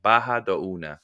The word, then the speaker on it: Barra do Una: I'll type it in our, it's Barra do Una. Barra do Una. The R's are pronounced like H's, the double R's in Brazil.